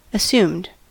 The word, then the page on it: assumed: American English